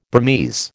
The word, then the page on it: Burmese: American English